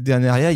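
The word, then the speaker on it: Dénériaz: Et vous remarquerez que Deneria, il y a un Z à la fin, ça ne se prononce pas, chers amis.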